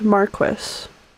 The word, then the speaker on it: marquis: Although the English form is pronounced Marquess, so.